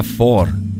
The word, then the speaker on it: Thor: Ma Thor si pronuncia Thor.